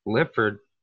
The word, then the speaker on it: Lipford: Today on the show, we got my man, Evan Lipford.